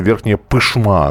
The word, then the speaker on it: Верхняя Пышма: И, кстати, спасибо, что исправили. Верхняя пышма, оказывается. Ну, будем на последний а, слог ставить хорошо. ударение.